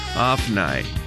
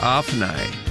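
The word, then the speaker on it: Ophni: OPA가 있어요. PH가. 그래서 아프나이. 한 번 더요. 아프나이. 오브니를 아프나이 이렇게 발음하시고 개바는 그대로 개바 G-E-B-A 개바 이렇게 발음하시면 되겠습니다 Well,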